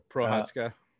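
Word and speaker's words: Procházka: Yeah, Prochazka.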